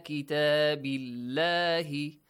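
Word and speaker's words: بِاللَّهِ: The lam in the word Allah, it is pronounced lightly. – Why? – Because before the word. – Before the word Allah. – Has kasra. – The letter ba has a kasra. So the letter before the word Allah has a kasra. So pronounce it with a light sound. Kitabillahi. Not kitabillahi.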